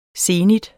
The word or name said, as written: zenit